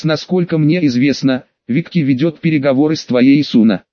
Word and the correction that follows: Суна: Суна, поправил я парня. Он сделал ударение на первой гласной. Суна.